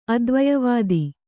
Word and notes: ಅದ್ವಯವಾದಿ: advayavādi

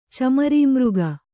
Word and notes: ಚಮರಿಮೃಗ: Kannada

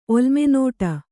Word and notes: ಒಲ್ಮೆನೋಟ: Kannada

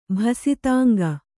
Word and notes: ಭಸಿತಾಂಗ: kannada

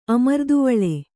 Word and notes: ಅಮರ್ದುವಳ್ಳಿ: amarduvaḷḷi

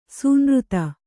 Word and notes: ಸೂನೃತ: kannada

